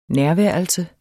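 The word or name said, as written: nærværelse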